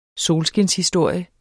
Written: solskinshistorie

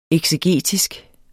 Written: eksegetisk

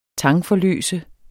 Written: tangforløse

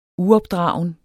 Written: uopdragen

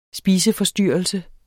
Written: SPISEFORSTYRRELSE

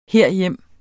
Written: herhjem

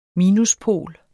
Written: minuspol

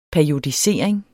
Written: periodisering

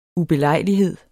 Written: ubelejlighed

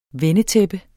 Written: vendetæppe

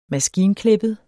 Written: maskinklippet

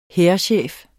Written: hærchef

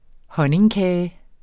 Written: honningkage

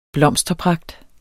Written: blomsterpragt

